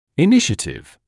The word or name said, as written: initiative